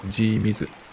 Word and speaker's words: G-MS: G-MS って書いて G i s と読むらしいですけど、 MSGB100 シリーズを 6 月 11 日に発売。